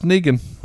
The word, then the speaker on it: Negan: Well, Kirkman came out and said it's pronounced Negan.